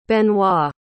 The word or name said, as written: Benoit